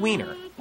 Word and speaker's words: Weiner: The speaker was referring to the Republican because Anthony Weiner, Congressman Weiner, and that is how you say his name.